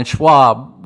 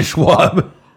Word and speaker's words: Schaub: Like comedians that we fucking listen to, Theo Von, we're talking about Joe Rogan, fucking Brendan Schaub with Theo Von, and who else? – Brendan, his name's Schaub. – Schaub, yeah, and whatever. He always bitches about people pronouncing it Schwab.